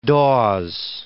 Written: Dawes

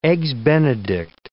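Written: eggshell

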